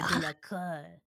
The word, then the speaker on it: de la Cour: Yeah, de la Cour.